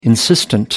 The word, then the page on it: insistent: American English